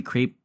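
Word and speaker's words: crêpe: We tried their savory crepe as well. Is it crepe or crepe? How do you say it? Crepe? Crepe. I've heard it said both ways, like mill crepe, mill crepe.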